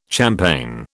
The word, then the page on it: champaign: British English